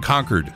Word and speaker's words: Concord: If you're in New Hampshire, don't say it any other way than Concord.